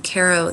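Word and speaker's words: Q'ero: And in the Caro tradition, Caro, it's spelled Q apostrophe E-R-O, they're ancestors of the Inca.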